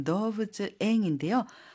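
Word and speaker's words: 動物園: 뒤에 원은 앵이라고 읽어요. 그래서 동물원은 도부츠앵인데요.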